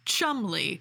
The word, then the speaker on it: Cholmondeley: I have recently learned that that is pronounced Chumley. – Damn it. – Because the English. – What are you guys doing to us? – Yeah, it's not Cholmondeley, it's Chumley.